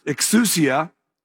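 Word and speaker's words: exousia (ἐξουσία): The word authority in Luke chapter 10 is pronounced exousia.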